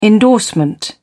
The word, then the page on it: endorsement: British English